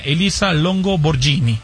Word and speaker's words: Elisa Longo Borghini: Y el tercer lugar, ya de la tabla general, fue para la italiana Elisa Longo Borghini.